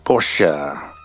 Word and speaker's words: Porsche: And she does have a Porsche, but she says it like Porsche. – Porsche. – Porsche. I barely pronounce the last part of the word. It's just Porsche.